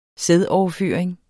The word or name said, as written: sædoverføring